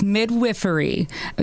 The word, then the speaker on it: midwifery: How do I say that correctly? – It is midwifery. Yeah, it is the midwife practices midwifery. It's funny, even midwives, before we go to school, a lot of us don't know how to say it properly as well.